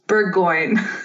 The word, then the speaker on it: Bourgoin: the French way, which is Bourgoin. But you also said that where you live, they pronounce it Bourgoin.